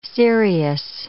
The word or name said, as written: cereus